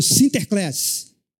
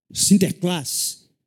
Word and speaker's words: Sinterklaas: encurtaram e a pronúncia holandesa ficou Sinterklaas. Sinterklaas. É o São Nicolau abreviado e na pronúncia holandesa.